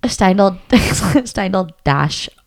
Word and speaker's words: Steindl-Rast: You know, both of us, we listened to a TED Talk by, I'm really butchering his name, but it's David Steindl-Rast. – Mm-hmm. – Steindl-Rast. I think that's it. – Yes.